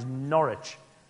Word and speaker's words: Norwich: Next largest town in England at this period of time is Norwich.